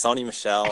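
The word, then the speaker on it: Sony Michel: First off, you have to pronounce Sony Michael. – It's Sony Michelle.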